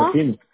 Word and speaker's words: Martínez: ¿O cuál Martínez. – Es su apellido? Digo. Oh, Martínez, con Z al último. – Martínez. Así es.